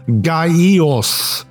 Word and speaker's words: Gaius: We'll put the spotlight on each of these three characters as we go along this week, starting with Gladheart, aka Gaius, which is technically pronounced as a three-syllable word, Gaios.